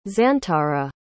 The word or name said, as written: xantara